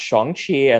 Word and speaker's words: Shang-Chi: On his birthday, like, I think like last month or a few months ago, he actually like posted like, so it's actually pronounced Shang-Chi, and this is actually how you pronounce my name.